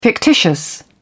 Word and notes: fictitious: British English

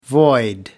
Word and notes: void: American English

